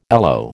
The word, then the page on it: aloe: American English